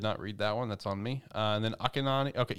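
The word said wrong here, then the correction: Akane-banashi: How is this thing pronounced? – So how I would say it would be Akani. Akani. Akani Manashi. – Akani Banashi. Okay, Akani Banashi.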